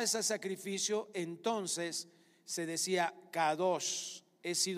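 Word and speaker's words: kadosh (קדוש): Pero la palabra santidad es la palabra kadosh con K. En el diccionario se escribe kodesh, pero la pronunciación es así.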